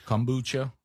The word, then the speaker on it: kombucha: You know, I have to go get my Baptist beer, kombucha, right? Did I say it right that time, Adler? – That's it.